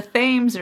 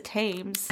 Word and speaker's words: Thames: We've come very far because we can now pronounce these things correctly. – Season one, you were like the Thames River. I was like, I think it's the Thames.